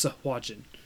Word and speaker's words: Sahuagin: Sahuagin. The proper pronunciation.